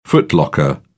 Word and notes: footlocker: British English

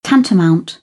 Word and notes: tantamount: British English

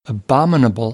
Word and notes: abominable: American English

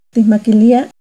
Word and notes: Ticmaquilia: Náhuatl

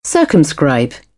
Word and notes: circumscribe: British English; ɜː

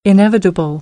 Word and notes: inevitable: American English

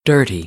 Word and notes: dirty: American English; ɝː - ɜː

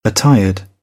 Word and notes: attired: British English

